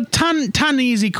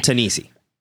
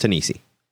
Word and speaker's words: Ta-Nehisi: So, Ta-Nehisi quotes, and I butchered that. I'm sorry. – Ta-Nehisi. Pronounced Ta-Nehisi.